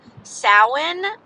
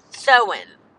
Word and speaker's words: Samhain: and have a blessed, prosperous Samhain, is how you pronounce it. Samhain. It's called Samhain. It's spelled Samhain. Samhain.